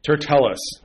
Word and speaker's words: Tertullus: And after 5 days, Ananias the high priest descended with the elders and with a certain orator named Turellius, who informed the governor against Paul. And when he was called forth, I'm not pronouncing the T. Tertullus.